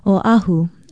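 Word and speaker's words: Oahu (Oʻahu): Oahu is spelled O-Okina-A-H-U. Although it is often disputed, many say that it means the gathering place. The main thing is to pronounce it correctly, Oahu.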